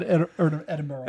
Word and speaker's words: Edinburgh: Because it's spelled that way, but it's said Edinburgh.